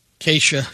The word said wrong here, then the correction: Kasia: K-A-S-I-A. Or Cassia. – Cassia, yeah.